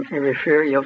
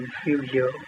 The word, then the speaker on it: Refugio: I was born in Refugio, Texas, R-E-F-U-G-I-O. Okay. Many people pronounce it Refugio. But if you're from Texas, and you can tell if they pronounce it Refugio, you know that's what it is.